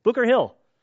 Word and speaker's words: Boucher: Booker Hill. Looks like B-O-U-C-H-E-R, but it's pronounced Booker.